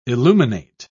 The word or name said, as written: illuminate